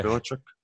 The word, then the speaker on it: Belichick: Thank you. Belichick. Did I say it right? Yeah. Belichick. Sorry. – No, you got it.